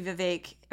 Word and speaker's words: Vivek: I think Vivek, it's actually Vivek. Everybody says his name wrong.